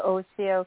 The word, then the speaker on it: Oshio: And through the years went to study traditional Chinese medicine, graduated with a diploma in acupuncture in 2004 from, you can pronounce it better than me, is it Oshio? – It's the Oshio College of Acupuncture and Herbology.